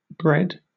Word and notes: bred: British English

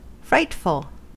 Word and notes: frightful: American English

